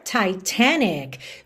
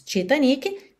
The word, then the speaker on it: Titanic: or Titanic. Já tá aí, ó, uma aula de pronúncia para você perceber as diferenças aí do português para o inglês. Temos a mesma palavra e pronúncias bem diferentes. Titanic, Titanic.